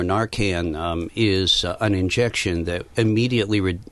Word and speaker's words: Narcan: Narcan, drug. – Narcan, naloxone.